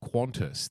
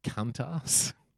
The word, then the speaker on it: Qantas: And I remember that she mispronounced Qantas, the airline, because she was obviously reading the teleprompter, I can only assume. Yeah. And Qantas is spelled Q-A-N-T-A-S, and she pronounced it Cuntas.